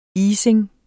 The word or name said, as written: Isingen